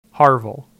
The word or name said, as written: Harvel